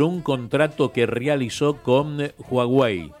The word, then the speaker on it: Huawei: Huawei. – La H es muda.